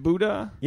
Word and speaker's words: Booda: Is it Booda? – Yeah.